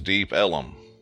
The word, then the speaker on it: Deep Ellum: because of Texas pronunciation, it became known as Deep Ellum.